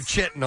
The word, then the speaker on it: chitin: Analysis of the elytra revealed that it's made of layers of, I guess you pronounce this chitin, C-H-I-T-I-N. – Yeah, like it's after you have a lot of Mexican food. – You're chitin all the time. – Chitin. Yeah, I think it's chitin, the hard K.